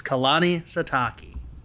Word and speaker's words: Kalani Sitake: And just FYI, before people fly off the gun and be like, doesn't know how to pronounce his name, it's Kalani Sitake.